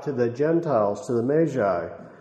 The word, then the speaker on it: Magi: I wouldn't either, except that I was working on this subject of the Magi. As you know, we're commonly understood as Magi, but it's actually Magi. That's the way it's pronounced, the wise men.